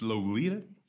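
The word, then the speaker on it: Loleatta: Que se, se escribe Loleata. Ok. No sé, pero es Lolita.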